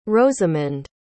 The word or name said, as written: rozemond